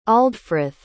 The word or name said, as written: aldfrith